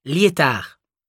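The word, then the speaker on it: Liétard: Lyétard. – Lyétard, comment ça s'écrit ? – L-I-E accent aigu T-A-R-D. – L-I-E accent aigu T-A-R-D.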